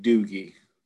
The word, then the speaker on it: Doogie: One, I need you to stop saying Doogie. – Apparently that's how he pronounces it. Because I've heard him in interviews and that's how he says it.